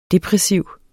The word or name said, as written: depressiv